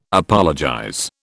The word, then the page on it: apologize: British English